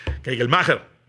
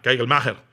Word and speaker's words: Kegelmacher: Kegelmacher. Es su, pronunciación correcta porque es este, de ascendencia alemana. Kegelmacher.